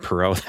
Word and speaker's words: Poirot: It's not Poirot. That's that's the no. It's Poirot.